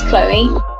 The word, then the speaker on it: Chloe: My actual name's Chloe. I call myself Cholly because people usually spell Chloe wrong. They spell it C-H-O-L-E instead of C-H-L-O-E quite often.